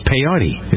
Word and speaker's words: peyote: There's a church in the Southwest that celebrates peyote.